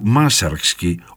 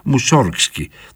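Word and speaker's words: Μάσαρξκι (Мусоргский): Τα σχετικά λίγα έργα του Μάσαρξκι, όπως τον προφέρουν οι Ρώσοι, Μουσόργσκι, τον έχουμε συνηθίσει στα ελληνικά,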